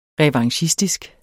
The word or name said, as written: revanchistisk